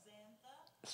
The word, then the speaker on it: Zantha: Xantha. – What? – Xantha. – Spell it. – Z-A-N-T-H-A. – Z-A-N-T-H-A.